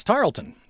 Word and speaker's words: Tarleton: Say it like this. Tarleton. That's it, like Laurel. That's how they said it. Like Laurel. Tarleton.